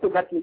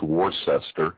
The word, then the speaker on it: Worcester: Wista, right? That's correct, isn't it? – Wista, you pronounced it exactly right, Wista. – Yeah, Wista. I've been there. Of course, if you pronounce it phonetically, it's Worcester.